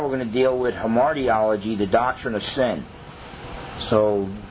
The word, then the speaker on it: Hamartiology: It's Hamartiology.